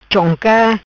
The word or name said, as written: Chonca